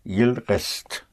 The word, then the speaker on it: restent: In spelling, il reste for he is staying is spelled R-E-S-T-E, but for they is spelled R-E-S-T-E-N-T. Okay. The E-N-T here is silent. So it's very important in reading, whenever you see an E-N-T with the ending of a verb, you know that it is silent and that means they.